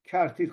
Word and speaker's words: کرتیر: البته تلفظ دیگری داره، تون در فارسی بده، کرتیر خونده میشه، و چند نقطه چه خواهد شد؟